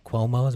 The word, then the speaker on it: Cuomo: Cuomo, I believe is how you say it.